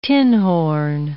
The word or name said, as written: tinhorn